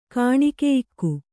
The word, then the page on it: ಗೋಣುಗೊಯ್ಕ: gōṇugoyka